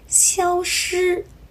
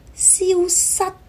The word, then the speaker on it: 消失: 正确的发音是 sick sick，消息，消息，消失，消失。注意了，消息和消失，西跟失，很多朋友在发音的时候很容易把两个字混了。消息的西是 sick sick，消失的失 sad sad。